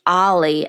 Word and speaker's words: O11Y: We were talking about O11Y, and not everybody knows that that is pronounced Ollie and means observability.